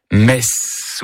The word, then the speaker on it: Metz: Il s'agit bien sûr du TZ qui finit Metz ou Metz.